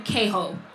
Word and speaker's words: Queho: So also one thing, most people pronounce his name Queho, but the Spanish pronunciation, because his name is derived from the word quejar, which means to complain. He was a complainer. So we're going to call him Queho.